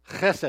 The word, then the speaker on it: chesed: The Lexham Bible Dictionary tells us that the most frequent noun for love in the Old Testament is the word pronounced chesed. And I won't ask you to pronounce it, but it's an important word, H-E-S-E-D.